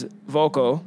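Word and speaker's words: Vulko: Is Volko.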